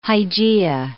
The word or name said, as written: Hygeia